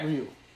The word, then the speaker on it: Ryu: Ryu. – He's in place for Max Scherzer. – And I will say Max Scherzer, who's taken the game off to deal with some injury stuff, Ryu has been the second best pitcher in the National League, so I have no qualms about it.